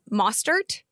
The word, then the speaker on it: Mostert: And I think at this point, I did waivers on one league, drop Derrick Henry for Raheem Mostert in San Francisco. Am I saying his name correctly? You are the 49ers expert. – Yes, that sounds right.